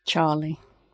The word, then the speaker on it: Txarli: Charlie. – Really? T-X-A-R-L-I. His name's Charlie. – Well, T-X in Basque is pronounced Ch.